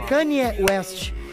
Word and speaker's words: Kanye West: É, é, que, é que assim, a pronúncia é Kanye West.